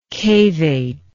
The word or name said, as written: cavate